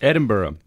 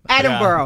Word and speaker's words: Edinburgh: How do you say it? – Edinburgh. – Edinburgh. – Yeah, there we go.